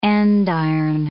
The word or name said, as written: andiron